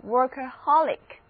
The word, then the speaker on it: workaholic: 这里字母 A 的发音是啊，所以念作 workaholic。